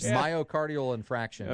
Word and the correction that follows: infarction: Okay. Infarction. – Infarction, yeah.